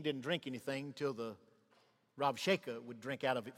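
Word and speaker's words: Rabshakeh: The B is pronounced as a V. The Rav Sheka is found in 2 Kings 18 and Isaiah 36.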